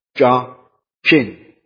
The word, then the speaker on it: Jachin: Jachin. CH there, I think, is pronounced as Chin.